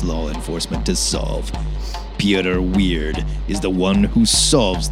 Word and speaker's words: Wyrd: My pick for next week is Weird, and that's spelled W-Y-R-D.